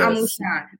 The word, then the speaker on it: Amusan: Amusan.